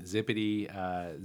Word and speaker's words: Zipede: But So the name Zipedy, Zip is quick and EDE is education.